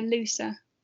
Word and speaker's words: Leuser: And this is the Lusa ecosystem. I think I'm pronouncing that right. How would you pronounce L-E-U-S-E-R? – Yeah, I'd say, yeah, Lusa.